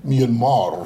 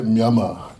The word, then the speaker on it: Myanmar: Det heter inte Myanmar. Det heter Myanmar. Myanmar den gången, om man ska vara exakt på burmesiska. Det finns inget R-ljud där.